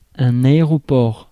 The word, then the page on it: aéroport: France; [a.e.ʁɔ.pɔʁ]